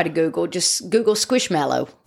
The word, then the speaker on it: Squishmallow: For Squishmallows? Are they? Am I saying it right? Squishmallow? – Yeah. – Squishmallow. – Yeah. – So, guys, look. – It's like a marshmallow.